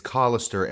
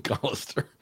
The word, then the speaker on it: Colister: By the way, if you have trouble remembering how to pronounce his name, it's Colister, as in colostomy bag. – Colostomy, Colister. Okay. – It's a little mnemonic device I use.